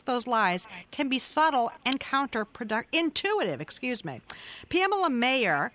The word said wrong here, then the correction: Meyer: Did I say that right, Pam? Is it Mayer or Meyer? – It's Meyer. – Meyer, I'm sorry.